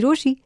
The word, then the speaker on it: Rochie: रोशी